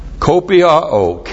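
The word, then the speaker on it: kopiao (κοπιάω): And it's modified by a present active participle of the verb kopiao, and this is the key to the self-discipline. This verb, kopiao, K-O-P-I-A, long O.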